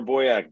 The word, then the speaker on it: Boyack: It's Connor Boyack. Boys that yak a lot.